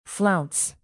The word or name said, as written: flounce